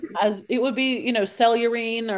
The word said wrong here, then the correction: cerulean: or whatever. Cerulean, that's I'm, how you say it.